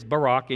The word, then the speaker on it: Barak: And that word is Barak. And yes, it's just like our former president, Barack Obama. That's how it's spelled and that's how it's pronounced.